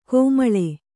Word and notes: ಕಮ್ಮಾಳ: kammāḷa

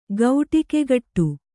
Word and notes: ಗೌಟಿಕೆಗಟ್ಟು: kannada

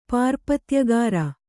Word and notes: ಪಾರ್ಪತ್ಯಗಾರ: pārpatyagāra; Kannada

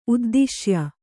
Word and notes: ಉದ್ದಿಶ್ಯ: uddiśya

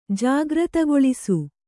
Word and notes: ಜಾಗೃತಗೊಳಿಸು: jāgřtagoḷsu

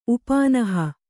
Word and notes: ಉಪನಾಹ: upanāha

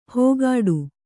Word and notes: ಹೋಗಾಡು: hōgāḍu

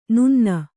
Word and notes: ನನ್ನಿ: Kannada

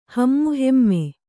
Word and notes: ಹೇಮಾಹೇಮಿ: kannada

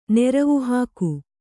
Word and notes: ನಿರ್ವಾಹಕಿ: nirvāhaki

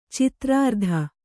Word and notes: ಚಿತ್ರಾರ್ಧ: citrārdha

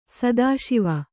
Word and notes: ಸಿತಶಿವ: sita Śiva

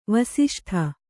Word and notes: ವಾಸಿಷ್ಠ: vāsiṣṭha

